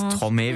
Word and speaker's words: Stromae: Von Stromae.